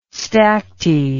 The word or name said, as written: stacte